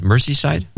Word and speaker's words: Merseyside: Merseyside.